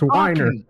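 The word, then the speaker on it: Winer: I hope Harry's listening to this, and every time you say his name, he goes, God damn it, it's Weiner.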